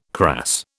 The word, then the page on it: crass: British English